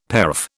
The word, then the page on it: paraph: British English